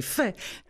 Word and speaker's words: FAIS: Mais non, pas FES, c'est pas possible. Vous prononcez fait, FAIS.